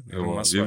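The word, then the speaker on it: Рамасвами: А, Вивек э, Рамосвами, да? – Рамосвами, Вивек да.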